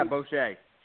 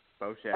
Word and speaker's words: Bosche: On the line now, we have best-selling author, political strategist, and entrepreneur success coach, Brian, is it Bosche? Bosche? How do I pronounce it? – Yeah, Bosche. Bosche.